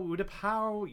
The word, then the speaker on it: Utapau: oh, here we go with pronunciation, Utapa Utapau, you know, the planet with the sinkholes where Obi-Wan faced down and beat General Grievous once and for all.